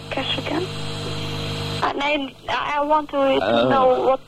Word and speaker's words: Castricum: Well, I know, but in Holland we say Castricum.